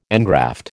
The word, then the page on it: engraft: American English